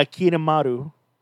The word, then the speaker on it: Kirimaru: I can't even pronounce it. They've done it with a K. Kirimaru, I think it was? – Yeah, Kirimaru. – Kirimaru, yeah, Kirimaru. I'm like, you're like, wait, how do you pronounce that name?